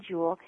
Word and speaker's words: Juhl: Yes, Diane Jewell. My first name is spelled D-I-A-N-N-E. My last name is spelled J-U-H-L, Diane Jewell.